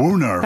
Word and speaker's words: woonerf: But the reason I love his email as well is because he says the best word in the Seattle Municipal Code is woo-nerf. Now, I had to look this up. – Woo-nerf, that's spelled W-O-O-N-E-R-F.